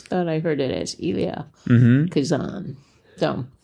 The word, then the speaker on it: Elia: I always thought it was Ilya Kazan. – It is Ilya, E-L-I-A.